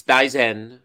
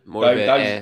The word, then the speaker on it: Daizen: So it's Daizen Maeda. – Close, it's Daizen. – Daizen. – Daizen. Pretty good Daizen.